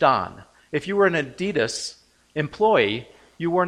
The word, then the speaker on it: Adidas: And if you go to Europe, they will pronounce it Adidas. We heard one of our teenagers in Ireland say Adidas, and I thought, what are you talking about? And they said, you know, the shoe company.